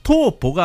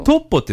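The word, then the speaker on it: Topo: なんですが、はあ、本来の発音ですとトーポが正しい。 – トーポ、うん、トーポジージョと発音するのが正しいんですね。 – なるほど。 – トッポっていうのはね、イタリア語ですと切り株になるので、お菓子あるでしょ。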